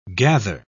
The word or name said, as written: gather